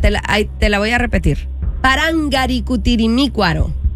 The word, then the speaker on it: Parangaricutirimícuaro: Parangaricutirimícuaro. – Me la mataste ahí. – Parangaricutirimícuaro. – No, le faltó una sílaba.